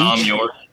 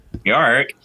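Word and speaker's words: Thom Yorke: Tom York with an H? – Tom York. – Yeah. – Tom York. That's how you're supposed to say his name. – York.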